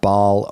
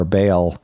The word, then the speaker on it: Baal: This god, Baal or Baal, sometimes called, sometimes pronounced, was the god of fertility.